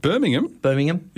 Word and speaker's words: Birmingham: Speaking of the Comm Games in Birmingham, because the H apparently is silent. Did you know that? – Birmingham. Is it? – Yep. The H is silent.